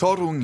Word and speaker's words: Toruń: Er ist 1975 in Torung habe ich das jetzt einig? Nee, nicht ganz richtig aus. Torung, ja.